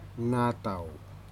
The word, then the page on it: nutau: slow